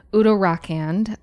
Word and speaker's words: Uttarakhand: I'm going to repronounce that. Uttarakhand. I believe that's the right way.